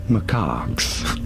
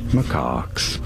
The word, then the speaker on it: macaques: How do you say it? Macaques. Macaques. Pretty straightforward. Once you know, you don't want to stress on the second syllable.